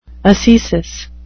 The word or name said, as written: ascesis